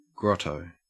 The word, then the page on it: grotto: Australian English